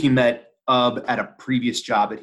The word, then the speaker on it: Ub: I say Ub. I know some people say Oob, but I always go with Ub.